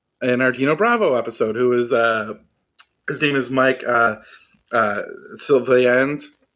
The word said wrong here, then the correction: Svillens: No, it's Mike Svillens. – Svillens, sorry. I always pronounce his last name wrong.